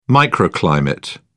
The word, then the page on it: microclimate: British English